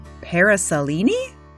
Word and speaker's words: paraselene: It's a parasolini? That's today's word. And if you're lucky enough to see it, you likely won't forget. Parasolini is spelled P-A-R-A-S-E-L-E-N-E.